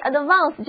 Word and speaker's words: advance: 包含 VANCE 这个词根最常用的单词是 advance， ADVANCE， AD 前缀表示朝向什么什么，而词根 VANCE 又表示前，所以 advance 就是动词前进或者发展增加的意思。第二个字母 A 要读作长的 R，租金预付款就是 advance rental payment， advance rental payment。